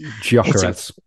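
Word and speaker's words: Gyökeres: Giocherez.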